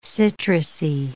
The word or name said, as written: citrusy